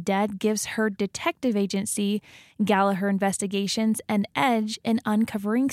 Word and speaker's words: Gallagher: So, well, actually, there's a little part in the book where they make fun of Americans for pronouncing their last name Gallagher. Uh-huh. And in Ireland, it's pronounced Gallagher. – Gallagher. – The second G is silent.